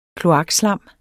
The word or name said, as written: kloakslam